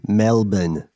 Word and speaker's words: Melbourne: Uh, Am I ah. saying that correctly? Melbourne. Yeah, yeah, that's right. Melbourne.